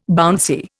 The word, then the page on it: bouncy: Indian